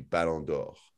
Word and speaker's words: Ballon d'Or: Is there a way, LV, tell me if you have an idea of how better to structure the Ballon d'Or and how better to set it up so that it seems more fair. – Okay, so, well, first things first, your pronunciation is incredible. Wonderful French.